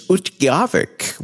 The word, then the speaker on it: Utqiagvik: I think it was 3 or 4 years ago, but the pronunciation is Utqiagvik, which is, and that took a while to learn how to say.